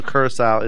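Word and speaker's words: Curaçao: Yeah, I think I keep wanting to call him Caraco, but it's Curacao. It has like that C that has that little like squiggly line on it. I guess makes like the S kind of sound. So Curacao is how it's pronounced.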